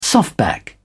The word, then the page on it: softback: British English